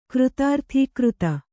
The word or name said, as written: ಕೃತಾರ್ಥೀಕೃತ